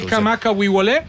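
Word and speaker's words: Kamaka Wiwole (Kamakawiwoʻole): Israel Kamaka Wiwole.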